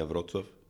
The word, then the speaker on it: Wrocław: Sería Wrocław, en polaco.